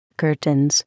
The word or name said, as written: curtains